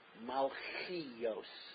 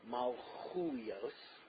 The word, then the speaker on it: Malchuyos: Some people say Malchios, but the correct grammatical pronunciation, although I'm not a tremendous expert in Diktik, is Malchuyos.